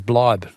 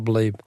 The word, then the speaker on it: bleib: Sorry to correct your German pronunciation, but stay in German is Bleib, not Bleib.